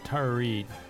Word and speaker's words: Tara Reid: I did not, but I would like to say that Tara Reid is pronounced Tara Reid.